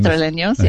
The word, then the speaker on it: Madrileños: I was reading one thing, that the usual escape for Madrileños, is that how you say it? People from Madrileños, yeah.